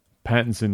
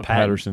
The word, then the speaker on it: Pattinson: Yeah, we always say his name wrong. Pattinson, not Patterson.